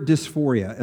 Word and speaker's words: dysphoria: Well, in 2013, the new DSM comes out with gender dysphoria as the language. Now, that is a very neutral term. Um, and, you know, what is dysphoria? Nobody really knows what that word means. It rhymes with euphoria, which sounds good, right?